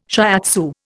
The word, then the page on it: shiatsu: Indian